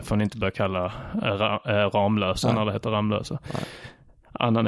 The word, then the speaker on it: Ramlösa: Det uttalas Ramlösa.